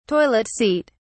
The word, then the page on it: toilet seat: British English